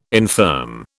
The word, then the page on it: infirm: British English